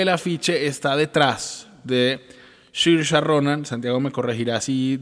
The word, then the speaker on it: Saoirse: Pero, pero no es Saoirse, como se escribe.